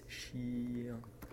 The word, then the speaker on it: chien: It's chien.